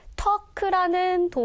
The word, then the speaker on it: talk: 자, 문장의 맨 그러면 앞에 나왔던 도입부였었는데 talk라는 동사를 볼게요. 지금 여기서는 투부정사 형태로 나왔는데요. talk에서 얘들아 봐봐. 스펠링이 T-A-L-K지만 이 단어는 특이하게 L 사운드가 나지 않아요.